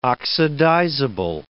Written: oxidizable

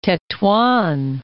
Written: Tetuan

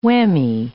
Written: whammy

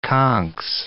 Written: conchs

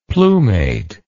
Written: plumate

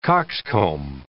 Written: cockscomb

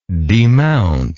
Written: demountability

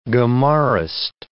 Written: Gemarist

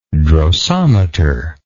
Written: drosometer

